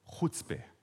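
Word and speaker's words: Chuzpe: Da gibt es ja diesen Begriff, ich weiß nicht, man, wie man das richtig sagt, ich glaube, der heißt Chutzpe, oder? Kennt ihr das? Sagt man, spricht man das so aus? Chutzpe. Gell? Das ist so eine Art, meine Frau, wie sag's mal laut? Chutzpe. Chutzpe. Das ist so eine Art ähm, Frechheit, Unverfrorenheit, Dreistigkeit.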